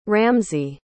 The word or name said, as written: ramsey